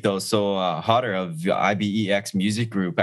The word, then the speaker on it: IBEX: IBEX.